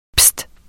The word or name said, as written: pist